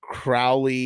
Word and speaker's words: Crowley: Okay, so Dagger says Crowley is pronounced like crow, but Ozzy says it the other way, so it will stay that way.